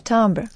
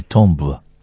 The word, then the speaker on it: timbre: But it's pronounced in French, timbre. – Yeah, but we say it in English and we say timbre.